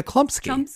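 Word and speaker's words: Chlumsky: Anna Chomsky? Is that how you say it? – Anna Klumsky.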